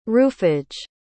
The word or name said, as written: roofage